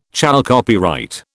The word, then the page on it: chalcopyrite: British English